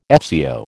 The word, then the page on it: FCO: American English